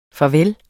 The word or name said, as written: farveller